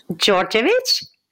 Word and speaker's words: Djordjevic: Djordjevic.